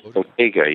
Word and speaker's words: Bottega: Bottega, is that how you pronounce it? – Bottega, yes.